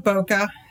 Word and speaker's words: Bokeh: What is Bokeh, or Bokeh, I should say? – Sure. Well, we pronounce it Bokeh, and it has to do with the photography term about fuzzy backgrounds.